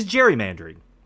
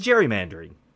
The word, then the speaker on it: gerrymandering: gerrymandering. Fun fact we say gerrymandering wrong. Most people use the soft G, but because it was named after Elbridge Gerry, it should be a hard G.